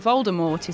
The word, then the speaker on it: Voldemort: Now, if only she'd included a paragraph that told us all the T in Voldemort is silent.